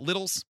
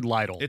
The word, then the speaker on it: Lidl: Is it Little's? – I've heard Little. I've also heard Lidl. – It's L-I-D-L.